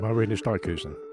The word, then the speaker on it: Marinus Dijkhuizen: Again, I think I'm saying his name incorrectly, but I'll go with it. – Marinus Dijkhuizen. – There you go.